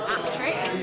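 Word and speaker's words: Appletreewick: which is spelt Appletree Wick, but I think it's pronounced Aptrick, and it's in the Yorkshire Dales, and it is just the most atmospheric little pub in the middle of this gorgeous village.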